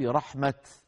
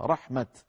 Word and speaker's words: رحمت: إن رحمة لو نظرتم في المصحف تجدون أنها كتبت بالتاء المبسوطة كما ينطقها بعض العرب، بعض العرب ينطقون هذه عند الوقف رحمة هكذا، هكذا بالتاء المبسوطة رحمة، وأغلب العرب ينطقونها عند الوقف بالهاء رحمة، لكنها